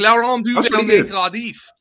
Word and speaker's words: Laurent Duvernay-Tardif: Laurent Duvernay-Tardif? – Yo, that's perfect. Laurent Duvernay-Tardif.